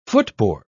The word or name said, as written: footboard